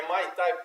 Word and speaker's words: Muay Thai: é Muay Thai. Tem tem um pessoal que pronuncia diferente, é né? – Muay Thai. Muay Thai. Muay Thai. Ah, Muay Thai.